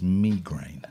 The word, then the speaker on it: migraine: Turns out the correct pronunciation of that is migraine. – Not on my watch, it's not. – It is because it's from the Greek.